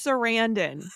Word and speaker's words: Sarandon: It's Sarandon, the first husband of Susan Sarandon.